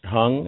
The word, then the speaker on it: Hang: He plays I think it's pronounced Hung. It's H-A-N-G.